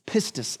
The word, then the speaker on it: pistis: This word in Greek is, is pistis, is how you say it.